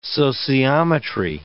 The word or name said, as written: sociometry